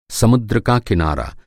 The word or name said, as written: किनारा